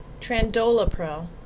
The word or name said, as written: Trandolapril